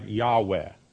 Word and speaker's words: Yahweh: Most modern scholars think it should be pronounced something like Yahweh.